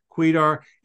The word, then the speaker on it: Kwedar: And if you're wondering how to pronounce Kwedar, obviously here on audio, it's Kwedar. It's spelled K-W-E-D-A-R. Quite an interesting name. Just think Queen.